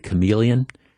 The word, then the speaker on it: chameleon: There was a villain, and the villain was the chameleon, but you spell chameleon CH. It's one of there are few words in the English language, but not many, where the CH, where the H is silent.